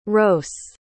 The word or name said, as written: ros